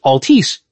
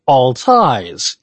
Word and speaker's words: Altice: His name, you would look at it and think it's spelled or pronounced Altice. No, it's Altice.